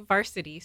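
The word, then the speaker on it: VRSTY: Well, no, like Varsity, so it's spelled without the vowels. – Oh, yeah. – Or so it's V-R-S-T-Y.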